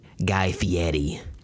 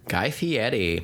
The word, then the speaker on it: Fieri: He spells his name, it looks like Fieri. – Yeah. – But it's like, I guess the Italian pronunciation of it, maybe. – Mm-hmm. – I don't know.